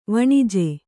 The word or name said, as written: ವನಜ